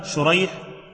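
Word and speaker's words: شُرَيح: فالكتابة شريح وسريج، الكتابة يعني مؤتلفة لكن النطق مختلف، لكن والآباء هما مؤتلفان. – الأول بالشين المعجمة والحاء المهملة، وهو تابعي يروي عن علي رضي الله عنه، والثاني بالسين المهملة والجيم، وهو من شيوخ البخاري، فهو النوع الذي يقال له المتشابه.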